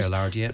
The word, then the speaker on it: Gaillardia: I planted a Gaillardia. I hope I'm pronouncing yeah, that right. Gaillardia.